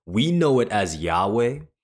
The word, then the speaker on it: Yahweh: And so we just simply threw in some vowels in there, and we call it Yahweh.